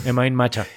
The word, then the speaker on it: Emain Macha: Pero bueno, Imain Maka, que se escribe Emain Macha. – Ok. – Suena vamos como... a decirlo. – Imain Macha.